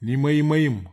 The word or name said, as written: nima imaim